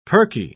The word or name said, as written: perky